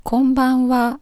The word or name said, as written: konbanwa